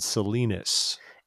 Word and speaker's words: Salinas: There's a city called spelled this. There's a city with the singular spelled the same way in Utah called Salinas. – That's probably why I say it's Salinas. – But in California, the city there is called Salinas.